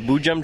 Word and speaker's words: boojum: Have you ever heard of a boojum tree? B-O-O-J-U-M? Saying that right? A boojum tree? – Yeah, boojum tree.